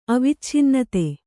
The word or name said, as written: ಅವಿಚ್ಛಿನ್ನತೆ